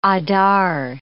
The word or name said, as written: Adar